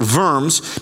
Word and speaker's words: Worms: And then they call him to appear before the Diet of Worms, W-O-R-M-S, just like the little squiggly thing. It's pronounced Worms if you're German, and it's the name of a town.